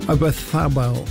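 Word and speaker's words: Oyarzabal: Defence, Rodri and Ruiz, Lamine Yamel, Olmo, Bayena and Ozabalaf. What is up front? I didn't say that right, did I? I know. Obathabel.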